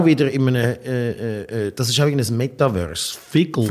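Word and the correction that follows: Phygtl: Wie spricht man das aus? Fügtel. Es heißt P-H-Y-G-T-L. Fügtel. – Fügtel.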